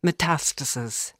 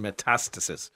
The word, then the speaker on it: Metastasis: Metastasis. – I beg your pardon, that's my mispronunciation, Metastasis.